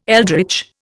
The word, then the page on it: eldritch: Indian